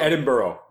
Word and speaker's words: Edinburgh: No, it's pronounced Burrow. Edinburgh.